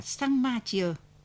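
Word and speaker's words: Champmathieu: Ở đấy tiếng răng, giọng địa phương đọc là xăng, cho nên mới gọi là Sang Ma Chia.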